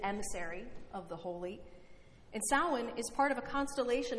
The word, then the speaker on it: Samhain: It looks like Samhain, if you've ever seen it, but it's pronounced Samhain.